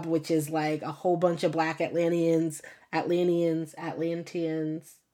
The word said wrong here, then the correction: Atlantans: Is that how you say it? Atlanteans. – I don't think so, but we're Atlantans.